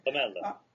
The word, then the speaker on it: PAMELA: I'm told you should pronounce Pamela in the Italian way. – Pamela, yes.